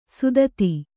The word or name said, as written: ಸುದತಿ